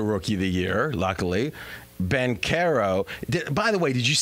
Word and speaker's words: Banchero: Banchero, yeah.